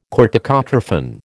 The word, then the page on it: corticotrophin: American English